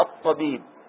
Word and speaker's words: الطبيب: تحذف اللام الشمسية وتدخل فيما بعدها، فعندما تأتي كلمة مثل هذه نقول جاء الطبيب، صار كأن الأل كلها غير موجودة، فينطق بها هكذا جاء الطبيب،